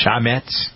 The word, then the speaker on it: chametz: Am I pronouncing it correctly? C-H-A-M-E-T-Z? Chametz? Somebody's... – Chametz. – Chametz. Okay, there we go. I knew I'd get it.